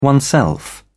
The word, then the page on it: oneself: British English